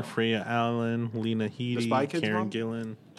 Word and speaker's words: Gillan: Yeah, Gillan.